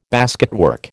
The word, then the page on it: basketwork: American English